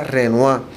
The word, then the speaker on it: Renoir: Se llama, se escribe Renoy, pero se pronuncia Renoir.